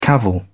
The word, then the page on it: cavil: British English